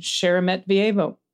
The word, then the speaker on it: Sheremetyevo: Sheremetyevo Airport near Moscow, maybe? – Thank you. Sheremetyevo.